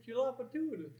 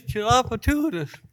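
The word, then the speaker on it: Tchoupitoulas: And, well, we had many Louisiana, New Orleans residents weigh in on exactly how to spell and say Chapatoulas. I can't say it either, but. – It's Chapatoulas. Right, well, let's get it one more time. – It is Chapatoulas.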